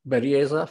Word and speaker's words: Berrelleza: I have with me Ramiro Berrelleza from Okteto.